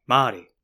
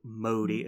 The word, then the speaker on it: Māori: Māori. – Māori. – Yeah, because also your mouth gets used to making. – Also, a lot of people say Māori, and it's not Māori, it's Māori.